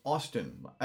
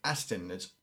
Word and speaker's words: Astin: Probably because when I first heard of him, I mispronounced it and thought it was Austin. A-U-S-T-E-I-N or E-N. I didn't know they were saying Astin.